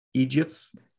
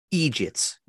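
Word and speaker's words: eejit: That's how that's how they say it over in the UK. That's my people say it. My people. You idiots. Okay. Can you say that? Can you say it? Egits. Egits. Yes.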